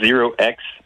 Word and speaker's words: 0x: Euh, puis, on, le prononce Zero X parce que le début des adresses Ethereum sur le blockchain commence tous par 0x.